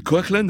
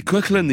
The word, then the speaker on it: Coughlan: Et alors, Coughlan a publié récemment un album solo, le premier depuis 10 ans, qui s'appelle Song of Coughlan, ça s'écrit C-O-A-K-L-A-N, ce qui est une clé, voilà, pour prononcer son nom, qui s'écrit Coughlan, si vous voulez.